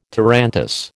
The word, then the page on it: tarantass: American English